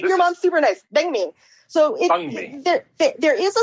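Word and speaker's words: bánh mì: But I think the easiest pronunciation is bun me or bang me.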